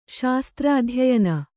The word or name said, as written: ಶಾಸ್ತ್ರಾಧ್ಯಯನ